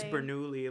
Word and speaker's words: Bernoulli: It's Bernoulli, at least the way I was taught.